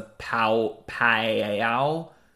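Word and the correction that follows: Paoa: I am so sorry, listeners. P A O A, Pawa, maybe Pawa. it's just Pawa.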